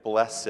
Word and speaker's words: blessed: Why in the world do we pronounce "blessed" with two syllables?